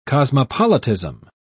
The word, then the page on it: cosmopolitism: British English